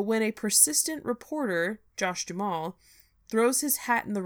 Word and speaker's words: Duhamel: It came out in 2010, starring Kristen Bell and Josh Duhamel, right? That's how you say it? – Duhamel, yeah.